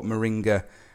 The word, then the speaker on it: moringa: Now, there's probably lots of people tuning in to the show that have no idea what moringa actually is. Am I even pronouncing that right? – You are. You're pronouncing that absolutely right.